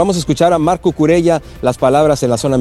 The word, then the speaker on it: Cucurella: Sí, no es Cucurella, es Cucurella, él mismo lo pronuncia de esta forma, y dice Cucurella que él prefiere jugar feo, que no le importa ser un fútbol horrible, pero seguir avanzando.